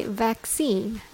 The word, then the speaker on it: vaccine: When you say vaccine, the stress is on scene.